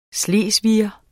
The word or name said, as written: slesviger